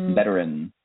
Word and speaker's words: veteran: Veteran. That's a fun word to say. – Yeah, I mean, it does have that other E in it.